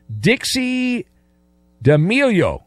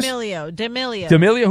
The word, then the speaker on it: D'Amelio: Dixie D'Amelio. Is that, am I saying that right? Yeah, but it's not 30, 25, 25. Is that an actress? – D'Amelio.